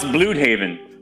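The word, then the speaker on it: Blüdhaven: But it's BLU, it's pronounced Bloodhaven. It's like German.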